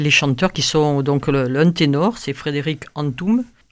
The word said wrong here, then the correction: Antoun: Antoun. Moi je prononce Antoun.